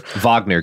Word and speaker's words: Wagner: Wagner, Garrison. It's important to pronounce things correctly. – Wagner. It's a W.